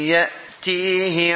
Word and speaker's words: يأتيه: Harusnya ya ti, ya nggak boleh ngambang. Ya tihi azabu yukhzihi.